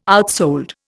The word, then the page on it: outsold: Indian